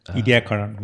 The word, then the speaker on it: Ediacaran: Yeah, I haven't heard or studied much about the whole snowball Earth back in the I always pronounce it Ediacaran. – Ediacaran, yeah. Uh-huh. Well, I might be saying wrong, but.